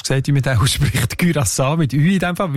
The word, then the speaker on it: Curaçao: Das sind eben die Aussprachendatenbank, die haben nämlich gerade letzten Dezember die Aussprachempfehlung geändert von Curaçao auf Curaçao, weil sie beziehen sich dort auf eine Aussprachenduden und auf das digitale Wörterbuch von der deutschen Sprache, wo beide eben Curaçao vorschreiben.